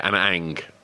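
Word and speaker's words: Ana Ng: Yeah, Anna Ang.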